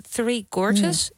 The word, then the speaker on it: Three Gorges: De Three Gorges, zeg ik dat goed zo? – Ja.